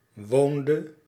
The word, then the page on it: woonde: /ˈʋoːn.də/